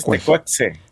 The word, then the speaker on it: Tecuexe: Sí, pues nosotros estamos acostumbrados a nombrar la X como J. – Tucuexe. – Pero un Tucueje. Experto en náhuatl nos dijo Tecueje. Que es Tecuexe.